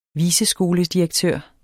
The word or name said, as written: viceskoledirektør